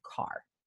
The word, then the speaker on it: Kerr: Although it's K-E-R-R, which I'm pretty sure the British pronunciation of that is Carr.